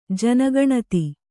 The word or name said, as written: ಜನಗಣತಿ